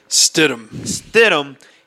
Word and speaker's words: Stidham: Stidham.